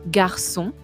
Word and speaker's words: garçon: Pero si quiero que en este caso la C se pronuncie s, le puedo agregar la cédille abajo. Por ejemplo en la palabra varón, garçon. Son se escribe con C, cédille o N, ¿sí?